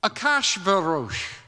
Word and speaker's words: Ahasuerus: In chapters 1 and 2, we meet the king, Ahasuerus. That's the way it's pronounced in Hebrew.